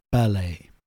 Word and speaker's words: ballet: Well, I just checked it and it is indeed ballet. Yep. British English pronunciation.